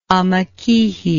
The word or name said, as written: amakihi